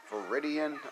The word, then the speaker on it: Viridian: Look, I got this damn Viridian. I want to say it. That's how you pronounce it.